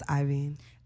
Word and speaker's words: Iovine: And our news and gossip for today is Dr. Dre and Jimmy Levine previously gave 70 milli to Levine. – How did I say it? – You said Levine, it's Iveen.